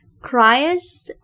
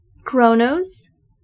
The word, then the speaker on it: Kronos: Cryus. – Kronos.